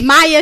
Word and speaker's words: Maia: Maya, I believe that's how you say it, M-A-I-A. Maya, yep.